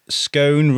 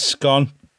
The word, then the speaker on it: scone: Dan Woodvine says it's a scone. Jane Pickworth says scone rhymes with phone. Jane, you know what you can fucking do too? I'd love you, really. Kate Harris says scone, as in gone.